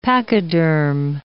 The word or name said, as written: pachyderm